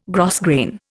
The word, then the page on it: grosgrain: Indian